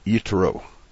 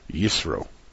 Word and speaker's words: Yitro: This show is for Parshat Yitro, or as we used to say, Yisro, which is not only in the Ashkenazis pronunciation, but it's also a misaccented word, Yitro, father-in-law of Moshe.